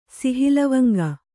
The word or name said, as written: ಸಿಹಿಲವಂಗ